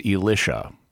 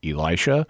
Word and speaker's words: Elisha: Because there is an evil conspiracy among people in Catholic liturgical circles who write Bible pronunciation guides to try to get people to pronounce the perfectly normal name Elisha as Elisha, which is just wrong on so many levels. It's not the standard English pronunciation, which is Elisha, and it's also not the Hebrew pronunciation, which is Elisha. This Elisha business is like every single syllable of it is wrong from a Hebrew perspective.